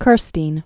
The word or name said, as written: Lincoln Kirstein